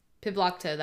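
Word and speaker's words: Pibloktoq: Pibluktalk. Because some people put a Q at the end, some people don't. – Mm-hmm. – Pibluktalk. Okay, that's how we're going to say it. Pibluktalk, that's what we're going to talk about today.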